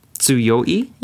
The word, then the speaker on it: 強い: Tsuyoi? – Yeah, desu.